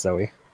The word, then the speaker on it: Zoe: It says Zoe. – It's pronounced Zoe. – Oh, did I? Yep. Zoe. That's so weird, isn't it? – I know. It's British. – English is an evil, unfair language.